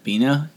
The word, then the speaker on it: Biena: Biena or Bina? – Bina. – Bina? B-I-E-N-A is the name. Bina.